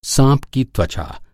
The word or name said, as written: त्वचा